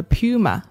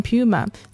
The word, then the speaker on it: puma: 今天我们看到这个 at large 的对象是 a puma 美洲狮。这个单词呢可以读成 puma，在美音当中也可以读成 puma 啊。